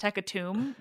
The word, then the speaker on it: hecatomb: Also, I think I realized recently it's pronounced hecatomb, which makes sense because it's tomb, but it just feels wrong.